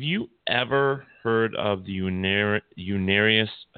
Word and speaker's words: Unarius: Unarius, yep. UNARIUS, Unarius.